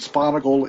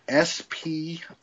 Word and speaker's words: Sponaugle: It's Patrick as the normal name, and Sponaugle is Sponaugle.